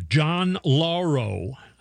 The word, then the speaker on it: Lauro: That's the new lawyer, John Lauro, I think is how you say his name. That's how people have been saying his name anyway.